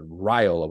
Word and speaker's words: Raiola: Yeah, Raiola. We go through the pronunciation thing and how I definitely messed that up last week. But upon further review, we did actually get the right pronunciation.